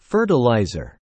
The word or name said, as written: fertiliser